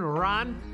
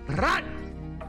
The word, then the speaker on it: Rahn: How you pronounce it? R-A-H-N, Ron. Ron!